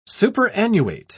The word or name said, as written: superannuate